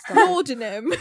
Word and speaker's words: laudanum: Laudanum. – No! Anyway, I was just begging it wouldn't come. – Okay, I don't know what... I don't know how to pronounce this. L-A-U-D-A-N-U-M. – I think it's laudanum.